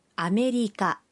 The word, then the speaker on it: アメリカ: أمريكا كارا كيماشتا أنا من أمريكا هكذا ينطق اسم البلد أمريكا أمريكا